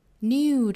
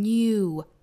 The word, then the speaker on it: new: sun comes a new day, new 这个词我们注意英音和美音的发法，一般呢英音是发成 new 中间有一个小小的耶的发音，但是美音呢会发成 new, 当然有的时候也听到过美国人读 new 哈。